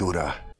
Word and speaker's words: Ura: Save your strength, Ura. – She's identifying herself as Ura. He pronounces it correctly with the rhotic R, and he assures her, like, he assumes that she can understand basic.